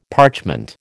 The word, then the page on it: parchment: American English